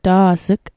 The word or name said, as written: ta's'g